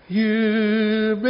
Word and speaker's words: Jubilate: I'm told I don't do Latin, but I'm told the J is silent. So it goes like this. – Jubilate Deo, jubilate Deo, alleluia.